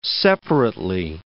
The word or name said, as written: separately